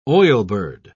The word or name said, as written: oilbird